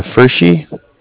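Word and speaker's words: Pferschy: Yeah, it's Frischi.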